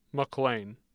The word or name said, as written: McLean